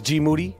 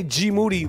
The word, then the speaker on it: Moody: So when I say G. Moody, last name rhymes with duty, that's just it.